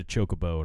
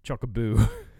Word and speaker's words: Chocobo: Chocobo is the correct pronunciation, apparently. Because that's how they pronounce it on the newer games. So, Chocobo. It's Chocobo.